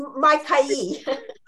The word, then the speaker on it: Maikai: Yes, Maikai.